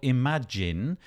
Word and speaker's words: Imajinn: It's called Imajinn, but it's not how you would imagine how it would be spelt.